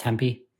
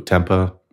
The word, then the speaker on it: Tempe: It's pronounced Tempe. – I don't know. – All right. – I think you're thinking of the fermented tofu, tempeh.